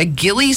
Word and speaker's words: ghillie: So they ordered a, and I may pronounce this wrong, a ghillie suit? – No, that's correct. – Is that right? – Yeah.